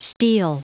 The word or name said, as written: steel